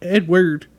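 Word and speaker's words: Eadweard: This dude's name is Edward, but I gotta think it's Edward, but the way it's spelled, mm-hmm. Edward, Edward.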